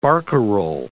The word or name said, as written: barcarole